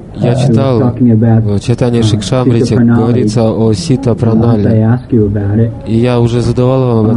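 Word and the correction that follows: сиддха-пранали: Прежде всего, нужно говорить Сидха Пранале.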